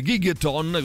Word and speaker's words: Gigaton: a 4 anni dal precedente Gigaton, così si pronuncia che Gigaton, vabbè, insomma, l'ultimo lavoro 2020 dei Pearl Jam, torna appunto il 19 aprile prossimo.